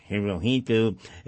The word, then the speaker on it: Hirohito: that's the H-I-R-O-H-I-T-O, Americans pronounce it Hirohito, it is pronounced Hirohito, the Emperor of Japan, and also Japanese Prime Minister General Hideki Tojo.